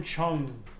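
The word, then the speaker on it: Cheung: How do you pronounce his name? Shung? – Jimmy Choing, I think. – Jimmy Choing or something like Choing. Choing. Choing. – No, that's awful. Jesus Christ. – Jim Chung.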